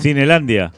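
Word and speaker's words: Cinelândia: Hay una, estación de, de subte en, Río de Janeiro que se llama Cinelandia. Cinelandia. Cinelandia. Con acento en la.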